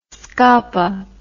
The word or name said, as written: scapa